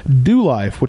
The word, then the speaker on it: DeuxLife: Second comes from DoLife, which is spelled DeuxLife.